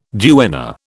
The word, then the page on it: duenna: British English